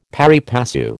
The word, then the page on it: pari passu: American English